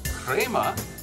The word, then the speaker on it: crema: And the foam, or crema, to pronounce it properly, contains concentrated sugars and oils from the beans, adding the light sweetness that helps balance out the bitterness.